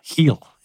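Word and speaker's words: Geel: Yeah, the actual pronunciation, I think, if I get it right, I'm not good at this. This is a town in Belgium called Giel. It's like in the back of your throat where you have to say this.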